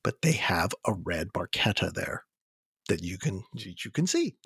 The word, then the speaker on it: Barchetta: It's funny about Red Barchetta because they mispronounced it. It's actually Barchetta. That's how you say the name of the car.